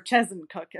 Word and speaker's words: Chezzetcook: He's from Porter's Lake, and we are from the small uh, community of East Chezzetcook or Chesencook as the locals say, which is which is right next door.